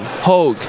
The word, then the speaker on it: Hoge: It's spelled H-O-G-E, like mine, Hogue.